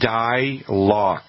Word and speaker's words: Dylox: But as you get into September, you want to use a product called Dilox, and it's spelled D-Y-L-O-X.